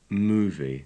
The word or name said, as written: movie